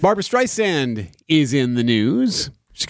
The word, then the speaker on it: Streisand: It's Streisand like sand on the beach.